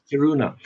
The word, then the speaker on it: Kiruna: So the nearest town's name in Swedish is Kiruna. You would probably pronounce it Kiruna.